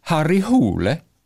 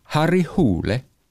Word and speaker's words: Harry Hole: Und sein Kommissar wird übrigens ausgesprochen Harry Hole. Weil viele sagen Harry Hole oder Harry Hole, aber er wird ausgesprochen Harry Hole.